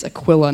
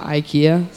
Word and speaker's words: Aquila: Um, and there he, met, uh, he made connections with Priscilla and Akia. Um, no, I said it wrong again. Um, when we were meeting with Pastor Nate on Thursday, it's Aquila, not Ikea.